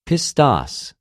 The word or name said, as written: πιστος